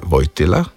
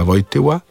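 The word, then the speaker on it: Wojtyła: oziroma Vojtila, bi kdo rekel po polsko. Tu moramo vseeno izpostaviti, da je ta Vojtila, ne, da smo še bolj pravilnejši, ne, je ta Vojtila v slovenščini ne sistemsko izgovorjen.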